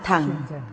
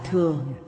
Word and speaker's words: thừa: Chữ thừa đọc theo cổ âm là thẳng, hiện thời đọc là thừa.